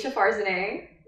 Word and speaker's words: Meesha Farzaneh: My name is Meesha Farzaneh.